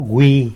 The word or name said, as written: wi